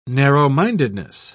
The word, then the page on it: narrow-mindedness: British English